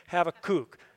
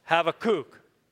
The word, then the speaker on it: Habakkuk: No, you typically in Hebrew emphasize the last syllable, not always, but most words. So say it with me, Habakkuk. Habakkuk.